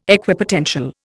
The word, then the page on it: Equipotential: Indian